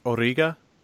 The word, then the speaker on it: Origa: He's as a musical guest along with Arija. – I'm just going to incorrectly correct your pronunciation. Isn't it pronounced Origa? – Yeah, it's Origa.